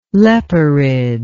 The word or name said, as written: leporide